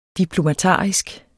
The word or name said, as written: diplomatarisk